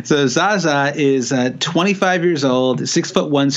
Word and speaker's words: Zaza: Zaza? – The Zaza, the famous Zaza of the greatest performance in Euro 2016.